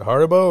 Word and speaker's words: Haribo: Haribo or Haribo? – Haribo.